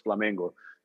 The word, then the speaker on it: Flamengo: The Belgians, the Flemish, That's how you say it in Portuguese, Flamengo. So it's actually like Flamengo.